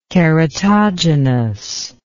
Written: keratogenous

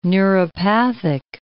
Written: neuropathic

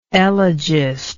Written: elegist

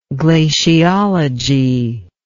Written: glaciologic

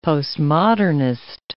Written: postmodernist

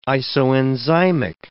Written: isoenzymic

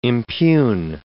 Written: impugn

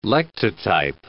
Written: lectotype

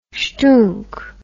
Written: shtoonk

